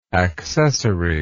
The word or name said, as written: accessoriness